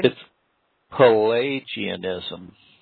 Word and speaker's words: Pelagianism: It's Pelagianism. A is a long A. Pelagianism.